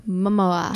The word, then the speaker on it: Momoa: I feel like it's like Momoa. – Momoa. – Is it Momoa? – Momoa.